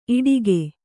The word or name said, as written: ಇಟಕು